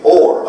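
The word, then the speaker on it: or (עור): So when, and this word is translated for skin, but it's the word or, O-O-R. And, you know, and that's how they pronounce it. And it sounds just exactly like this word, with the exception of the first glyph. Spelled almost the same way. This is the Aleph, the Wav, and the Resh. Okay? And this one also is pronounced or.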